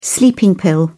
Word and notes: sleeping pill: British English

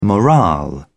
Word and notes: morale: British English

